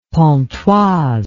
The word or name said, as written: pontoise